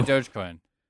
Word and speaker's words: Dogecoin: Yeah, Dogecoin.